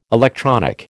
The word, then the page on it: electronic: American English